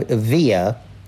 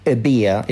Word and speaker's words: Evia: And it was in an area between the Greek island of Evia, or Ebia, it's, it, it's pronounced both ways, uh, and the Greek mainland.